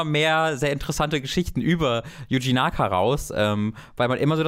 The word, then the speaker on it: Yuji Naka: So Yuji Naka.